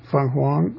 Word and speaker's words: Feng Huang: With Feng Huang, that's not the right pronunciation, or is it? Yes, it is. Feng Huang, the Chinese phoenix design.